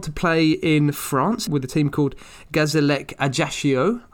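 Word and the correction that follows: Gazélec Ajaccio: I probably haven't pronounced that very well. Do you want to... let's try that again in a more French accent. Gazelec Ajaccio. Is that better?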